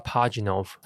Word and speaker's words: Pajitnov: 我们叫他 Pajitnov 好了，叫他 Pajitnov，用姓氏嘛。 – 用姓氏。 – 好，其实我看书的时候蛮痛苦，因为一开始俄罗斯的姓氏都很长，还有什么什么夫什么什么的，然后对对对对对，我都觉得很容易。 – 没有，其实我觉得用念英文还比较发音也比较清楚一点，我们就念，我们就叫他 Pajitnov。